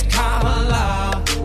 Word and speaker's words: Kamala: It's not Kamala, no, her name is Kamala. Duh. emphasize the first syllable, it's Kamala.